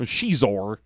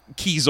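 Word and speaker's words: Xizor: So he's got a broken chair so that we can learn that his name is pronounced with a soft X. – There you go. – So it might as well be spelled S-H-E-Z-O-R. – But Shizor.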